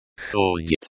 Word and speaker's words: ходит: Ходит, the stress is on the first syllable, the ho. It sounds like ходит.